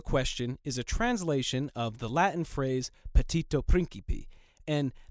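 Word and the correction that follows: petitio principii: It's actually petitio principi. Note the missing I in my original pronunciation.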